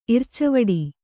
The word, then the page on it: ಇರ್ಚಾವಡಿ: ircavāḍi; kannada